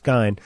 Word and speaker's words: Gein: Everybody, for all these years, everybody's pronounced it Gein for years, but as Orion has pointed out, it's not. It's Gein.